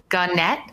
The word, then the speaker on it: Gannett: Prior to joining AES, she held roles in financial reporting and analysis at Gannett. Sorry, did I say that right, Sarah? – Yes, you did.